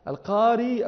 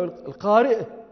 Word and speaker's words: القارئ: القاري أو القارئ من أيش؟ من القراءة. وقد تسهلون فتحذفون الهمزة وتقولون القاري، وهي لهجة من لهجات العرب.